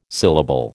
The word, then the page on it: syllable: American English